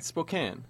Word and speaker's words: Spokane: How are you supposed to say Spokane? – Spokane. – Oh, for Spokane. God's sake. It's an E at the end. It's a long A.